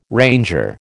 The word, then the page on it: ranger: American English